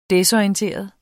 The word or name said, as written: desorienteret